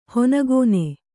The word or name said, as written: ಹನಿಗಣ್ಣು